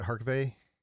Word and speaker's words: Harkavy: Did you guys see the tweet that Juliana Harkavy, is that how you say it, Harkavy? – Uh-huh.